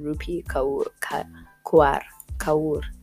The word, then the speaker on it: Rupi Kaur: And yo, I think that's how you pronounce her name. Rupi Kaur. Yes.